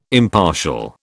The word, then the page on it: impartial: British English